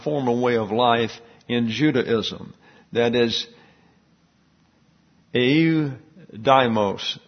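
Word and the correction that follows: Ioudaismos: I mispronounced that. Eudaismos. That little double dot there makes it a different syllable.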